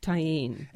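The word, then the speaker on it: Tajín: It's spelled T-A-J-I-N. Tahine.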